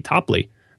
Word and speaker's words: Toplee: So that's what I want to say to John Topley, who was very nice, who gave pronunciation of his name, T-O-P-L-E-E, Topley.